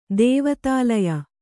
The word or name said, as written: ದೇವತಾಲಯ